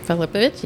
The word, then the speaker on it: Filipovich: Filipovich? Yep, you did. – Filipovich. – Four syllables.